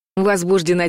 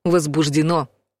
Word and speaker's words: возбуждено: Правильное ударение в слове падает на последний слог. Возбуждено. Если вы услышали ударение на втором слоге «возбуждено», возможно, перед вами полицейский, юрист или просто человек, который тесно связан с судами.